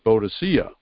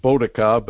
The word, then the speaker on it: Boadicea: Boadicea. Some people pronounce it Boadica, but you know, a lot of people know it as Boadicea.